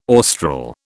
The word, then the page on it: austral: British English